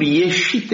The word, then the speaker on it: ieșit: Cuvântul era însă pronunțat în mod sigur ieșit exact ca astăzi,